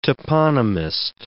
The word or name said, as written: toponymist